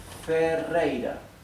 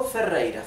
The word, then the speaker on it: Ferreira: This R in Ferreira, in my last name, is doubled. Therefore, it is pronounced rrr, rei, rrr. This last R is not doubled and is in the middle of the word, therefore it is pronounced r, right? The soft sound, ra, r, ra.